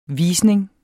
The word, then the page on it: visning: ˈviːsneŋ